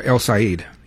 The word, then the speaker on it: El Sayed: Now, as far as Mike Rogers and El Sayed, is that how you pronounce his name? – Yeah, El Sayed.